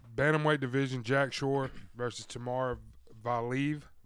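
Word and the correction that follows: Timur Valiev: Tamir Valiev, I believe is how you say it.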